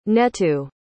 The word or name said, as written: neto